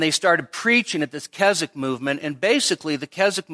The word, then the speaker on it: Keswick: Then there was the Keswick Convention, and that's Keswick, don't pronounce the W. And around the 1870s or so, there was a conference called the Keswick Conference in England.